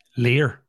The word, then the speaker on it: Llyr: I went to school with a kid called Llyr. So like double L-Y-R. – Llyr.